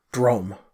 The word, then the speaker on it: Droaam: So we'll move over to the east then, to the nation of Drome, okay? It's spelled D-R-O-A-A-M, but it's pronounced Drome. – Okay. – Okay, like D-R-O-M-E.